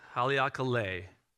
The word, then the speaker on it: Haleakala: Am I saying that right? No. I looked it up. How do you say it? – Haleakala. – I don't. Fine. Haleakala.